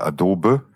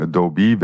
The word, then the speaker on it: Adobe: Und also weil in Deutschland sagt man ja Adobe, ja, falsche Betonung, Adobe wäre richtig.